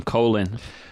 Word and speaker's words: Colin: They call him Colin. – Colin Powell. – Sorry. Not Colin, as sorry. – It's spelled.